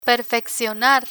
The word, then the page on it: perfeccionar: Spanish